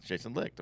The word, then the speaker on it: Licht: Is it Jason Licht? – Yeah, Jason Licht, of course.